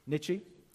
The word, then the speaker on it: Nietzsche: But he was influenced by a philosopher called Friedrich Nietzsche, is that how you pronounce it? – Nietzsche.